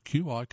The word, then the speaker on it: Qi: All are QI compatible. Q is pronounced Qi.